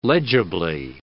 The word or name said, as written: legibly